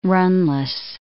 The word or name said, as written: runless